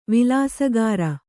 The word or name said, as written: ವಿಲಾಸಗಾರ